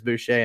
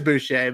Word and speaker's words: Boucher: And then you add another big man in Boucher that, and I hope I'm saying that right. I'm pretty sure it's Boucher and not Boucher. – I'm pretty sure you got it. Yeah, I think it's Boucher.